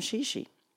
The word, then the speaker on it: Xixi: This morning, I got an email from Shishi. XIXI, which I didn't know how to say it until she arrived.